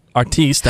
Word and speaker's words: Artiste: Artiste, that's how you pronounce it.